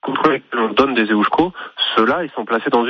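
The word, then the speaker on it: Eusko: Non, mais vous faites bien déjà, vous dites EUSCO, donc on va déjà le prononcer correctement.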